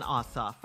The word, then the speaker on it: Ossoff: We want to vote for Reverend Raphael Warnock and Senator and John Ossoff.